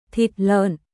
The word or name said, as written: Thịt lợn